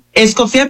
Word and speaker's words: Escoffi: ¿Es Escoffi? ¿Cómo pronuncio tu apellido? Perdón. – Escoffi, pero como gustes pronunciarlo, porque lo conocían de mil por más.